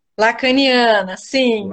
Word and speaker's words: Lacaniana: É psicanalista, ela é formada pelo Instituto de Psicanálise Lacaniana, tá certa a pronúncia? – Lacaniana, sim. – Lacaniana.